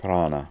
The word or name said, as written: Prana